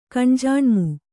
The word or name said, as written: ಕಣ್ಜಾಣ್ಮು